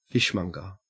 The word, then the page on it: fishmonger: Australian English